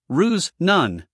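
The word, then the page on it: ruse: uk ruːz; en-us ruːz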